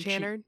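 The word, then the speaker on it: Channard: Chanard? Is that how you say his name? – Chanard.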